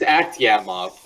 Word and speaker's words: Akhtemov: Yeah, it's Akhtemov, but, you know, whatever.